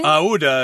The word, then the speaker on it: Aouda: Now, one major reason Fogg and Passepartout can afford to rescue Princess Aouda. How do you say her name again? – Aouda is what Aouda.